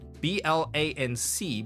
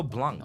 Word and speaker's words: blanc: And then blanc or blanc. Mm. Sometimes you pronounce, you know, I would do that, you know, pronounce the C mm. still. – B L A N C, blanc, we all know in many, many instances, including your white wine.